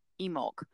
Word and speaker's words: Imbolc: So that means while you up there in the Northern Hemisphere are celebrating Lunasa, down here in the South, we will be celebrating Emulk. So it's spelled I-M-B-O-L-C, but the old spelling is O-I-M-E-L-C, which is Gaelic, and it literally translates to mean ewe's milk.